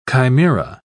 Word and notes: chimaera: British English

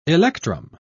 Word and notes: electrum: British English

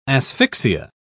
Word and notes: asphyxia: British English

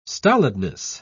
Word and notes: stolidness: British English

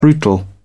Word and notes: brutal: British English

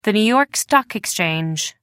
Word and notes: new york stock exchange: American English

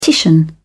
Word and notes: titian: British English

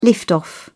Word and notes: lift off: British English

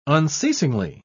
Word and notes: unceasingly: British English

